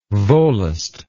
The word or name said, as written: volost